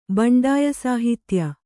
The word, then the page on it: ಬಂಡಾಯಸಾಹಿತ್ಯ: baṇḍāya sāhitya